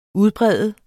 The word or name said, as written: udbrede